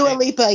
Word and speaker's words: Dua Lipa: Yeah, Dua Lipa, yeah.